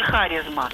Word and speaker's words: харизма: Потому что я где-то читала, что вот слова, пришедшие в русский язык из греческого, на них ударение, в них ударение ставится на третий слог с конца. И тогда вот катарсис и харизма. Но вот если катарсис еще я когда-то слышу... – А как же катарсис? – Ну вот харизма никогда не говорят, говорят харизма.